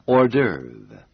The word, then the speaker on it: hors d'oeuvre: It is spelled H-O-R-S and D apostrophe O-E-U-V-R-E. It's pronounced hors d'oeuvre. – 大家一定感到这个字的拼法很奇怪。是的，这个字本身是法文，所以它的拼法和一般英文拼法不太一样。我们再请 给大家拼一次。 – Hors d'oeuvre. H-O-R-S and the last part is spelled D apostrophe O-E-U-V-R-E hors d'oeuvre.